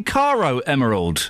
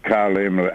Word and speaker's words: Caro Emerald: I don't know a Carla Oh, do you mean Caro Emerald? – All right, all right, all right. – Well, if you're phoning up to defend her, try and get her name right.